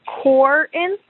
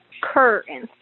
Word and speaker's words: Corinth: It is not Corinth. It would be Corinth, like a C-U-R, Corinth. Corinth.